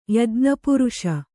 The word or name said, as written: ಯಜ್ಞಪುರುಷ